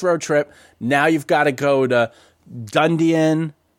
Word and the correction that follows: Dunedin: I always say it wrong. Whatever. – Dunedin, it's, yeah. – Dunedin.